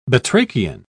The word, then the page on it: batrachian: British English